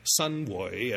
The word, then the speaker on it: Xinhui: Shi Xiangku was born somewhere around Xinhui, Sunhui, as it's pronounced in botched Cantonese.